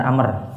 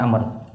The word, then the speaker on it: Amr: Kalau di sini amr. Yang benar amr. Karena ain mim ro ada waw berarti bacanya amr.